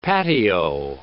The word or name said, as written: patio